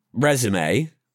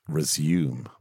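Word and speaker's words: résumé: Like, unless he's lied on his resume, American word. I looked it up and yeah, he was 22 when he was in Friends. He's like... – We pronounce it resume. – Resume, yes, we do.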